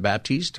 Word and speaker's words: Baptist: You're listening to the Pastor Scott Show, and is it Eric Baptist or is it Baptiste? How do I say it? Am I saying it right? – It's Baptist, just like the church.